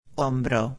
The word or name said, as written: hombro